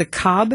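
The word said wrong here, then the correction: Cobh: Heritage Center? – It's actually Cove. – Cove. – Like Lake Cove, yeah. – Like Siobhan, okay. – Yeah, like Siobhan, exactly.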